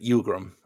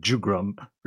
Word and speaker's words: Jugrum: Well, I thought it was, I guess it is kind of like a Y sound. You're right. Yugrum. I thought it was like an H sound, but I guess Y is probably more accurate. – But it is spelled with a J, but it's just not, not Jugrum or Jugrum, I guess.